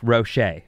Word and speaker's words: Roget: It's R-O-G-E-T, yeah. – But it's pronounced Rocher. – Oh! I always call it Roget's thesaurus.